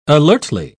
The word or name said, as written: alertly